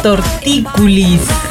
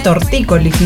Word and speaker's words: tortícolis: La palabra correcta, bueno, pronunciándola correctamente sería tortícolis, ¿no? No tortículis.